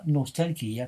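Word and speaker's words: Nostalgia (Ностальгия): si insiste sull'utilizzazione della pronuncia russa, Nostalgia, che è un viaggio di un intellettuale attraverso l'Italia.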